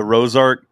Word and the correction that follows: Rorschach: yeah, or was it Rosark? Rosarch. I can't even say it. I used to know how to say it. It's a weird name. – Oh, Rorschach. – Rorschach. There you go.